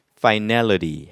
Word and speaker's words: finality: 그런데 이때 발음이 final이라고 하진 않겠죠. 그래서 이때 발음은 finality 하면서 발음이 바뀝니다. 에 강세가 있어요. 그래서 finality.